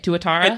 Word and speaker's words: Tuatara: Is this an acceptable way to pronounce it, by the way? Tuatara? – It is an acceptable way to pronounce it. It's not quite the technically correct way to pronounce it, but 99% of people will pronounce it that way, and it is sufficient.